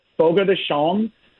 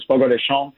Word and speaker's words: Fogo de Chão: So Fogo de Chão, which is a lot of people call it Fogo de Chão. That's what it looks like it's spelled like. But the Brazilian is Fogo de Chão.